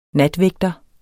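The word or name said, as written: NATVÆGTERES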